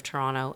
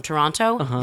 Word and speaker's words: Toronto: By the way, the sign of someone who's really from Toronto, they never say Toronto. – What did I say? – Toronto. – Toronto. – The last T is silent. No one from Toronto says Toronto.